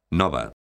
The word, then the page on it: nova: galega